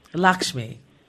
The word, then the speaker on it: Lakshmi: Lakma, how do you pronounce her last name? – I think it's Lakshmi, isn't it? Her last? – Name? – Lakshmi. – Yes, yeah, it is.